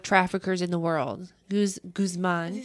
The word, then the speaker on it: Guzman: Joaquin El Chapo Guzman which is not how you pronounce his name. – Guzman. – Yeah, thank you.